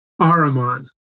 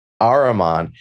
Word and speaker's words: Ahriman: and please pronounce the other name for me, Ahriman. – Ahriman, with Lucifer representing excessive spirituality and unbounded idealism, and Ahriman being the material world.